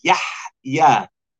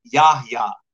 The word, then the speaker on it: Yahya: My name is Yahya. I get called regularly Yahya, Yahya, right? Yahya is a very particular statement and pronunciation due to that letter.